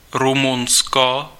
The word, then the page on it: Romania: /ɹəʊˈmeɪ.ni.ə/